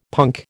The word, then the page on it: punk: American English